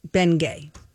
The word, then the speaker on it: Bengay: Because you put the emphasis on the wrong part. It's Bengay.